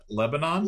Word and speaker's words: Lebanon: It's like L-E-B-A-N-O-N. How do you pronounce that? Lebanon? – Lebanon. No. Lebanon. I know that one.